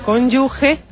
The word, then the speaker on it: cónyuge: Bueno, pues cónyuge, hoy te has despistado y porque cónyuge es G-E. Con... Se escribe G-E. – Aunque el sonido sea J.